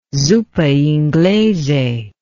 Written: zuppa inglese

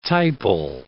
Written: typal

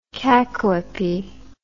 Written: cacoepy

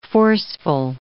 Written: forceful

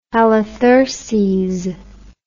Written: Halitherses

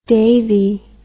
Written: davey

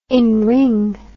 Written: enring

